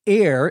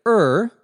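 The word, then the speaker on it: err: You can see why people pronounce the word air instead of er, because we also have the word error, which is pronounced with that air sound instead of an er sound.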